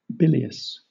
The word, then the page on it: bilious: British English